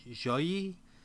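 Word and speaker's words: Xiaoyi: it's X-I-A-O-Y-I, so Joyi, a.k.a.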